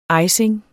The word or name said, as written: icing